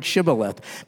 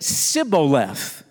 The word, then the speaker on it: Shibboleth: It says, they would say to them, Jephthah's men would say to the Ephraimites, say now, Shibboleth. Say that word, Shibboleth. But he said, Sibboleth. The Ephraimite would say, Sibboleth. See, he couldn't pronounce the word correctly.